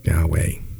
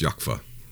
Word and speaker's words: Yahweh: The closest, from what I've studied, it's not Yahweh, it's Yahweh. – Mm, yeah, because of the no vowels. – Well, a short A sound. Wait, A-E-I-O-U, A-A-I. Yahweh. So it's a U sound, an English short sounding U, Yahweh.